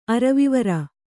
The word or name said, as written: ಅರವಿವರ